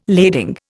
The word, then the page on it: lading: Indian